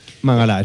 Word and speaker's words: Mangala: ¿Se pronuncia Mangalá o Mangala? – Mangalá, es francés. Mangalá. O sea, todo lo francés acaba en A.